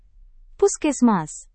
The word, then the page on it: puskesmas: Indonesian